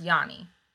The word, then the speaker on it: yoni: Yeah, it's yoni, but it me oh, it's spelled differently, but it's pronounced yoni. So it's Y-O-N-I. It's a Sanskrit word.